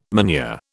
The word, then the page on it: manure: British English